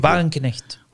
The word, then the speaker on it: Wagenknecht: Y luego está eh, Sara Wagenknecht o Wagenknecht. ¿Cómo se dice? Wagenknecht.